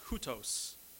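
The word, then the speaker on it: houtos (οὕτως): It's pronounced houtos. Houtos.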